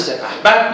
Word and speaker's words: سپهبد: تلفظ سپه بر همینجور که من تلفظ از سراسر شاهنامه توجه کنید این از مواردی که با اون صورت پهلویش مختلفه چون اینو محل قافیه به ما میگه که وقتی با بد قافیه میشه پس بنابراین تردیدی در تلفظ سپهبد ما نداریم ولی اصلش میدونیم سپهبد بود یکی نام و نام افغان بون کی؟